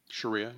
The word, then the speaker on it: Sharia: 15 countries that have, what is it, Sharia law? Is that how you say it? – Sharia, yeah.